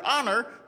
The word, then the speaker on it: honor: And the only thing I could think of that was tricky about the word honor was the silent H. She thinks I'm going to forget that. I will not forget the H. Honor. H. O-N-E-R.